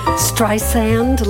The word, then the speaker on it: Streisand: Barbra Streisand. – Streisand, like sand on the beach, soft S.